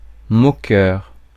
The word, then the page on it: moqueur: France; [mɔ.kœʁ]